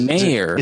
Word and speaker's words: Mayer: But yeah, I think Michael Meyer, love his name. And are you purposely saying his name wrong, though? Because Mayer. – Is it Mayer? – Yeah.